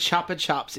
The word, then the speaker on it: Chupa Chups: It's pronounced Chupa Chups in the US. – Yeah. And Chupa Chups in the UK.